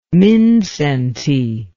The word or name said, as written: mindszenty